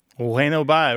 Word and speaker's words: bueno bye: So it's bueno bye with the B barely pronounced, right? Pronounced like a W? – Yep. Yep, exactly. – Bueno bye. – And it's all one word.